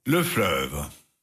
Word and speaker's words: Fleuve: Le Fleuve, tror jeg det udtales. – Fleuve.